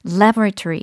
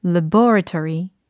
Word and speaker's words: laboratory: 이게 미국식으로는 이름절 강세가 됩니다. 그래서 laboratory 이렇게 발음이 되는데 영국식의 경우는 laboratory 이렇게 laboratory 네, 강세의 차이와 그리고 이름절의 모음이 살짝 변화가 됩니다. laboratory와 laboratory 이렇게 차이가 있죠.